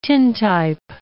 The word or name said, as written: tintype